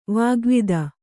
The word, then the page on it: ವಾಗ್ವಾದ: vāgvāda